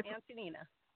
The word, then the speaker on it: Antanina: Antanina.